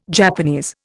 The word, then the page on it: Japanese: Indian